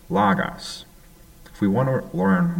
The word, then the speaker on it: logos: It's pronounced logos, as you can tell.